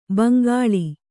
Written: ಭೋಂಕಲ್

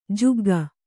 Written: ಜಗ್ಗು

